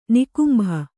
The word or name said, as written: ನಿಕುಂಭ